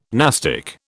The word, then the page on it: nastic: British English